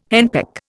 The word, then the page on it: henpeck: Indian